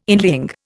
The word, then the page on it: inlying: Indian